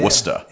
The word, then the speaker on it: Worcester: Yeah, Worcester.